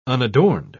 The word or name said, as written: unadorned